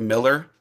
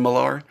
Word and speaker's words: Millar: You have the comic book writer Mark Millar, but it's actually Mark Miller. Just everyone calls him Millar.